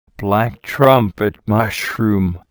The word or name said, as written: black trumpet mushroom